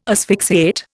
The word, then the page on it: asphyxiate: Indian